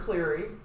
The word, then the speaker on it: Cleary: It was the murder of Bridget Cleary, and I hope I'm saying that last name right. Apologies if I mispronounce it. – It is Cleary, and okay.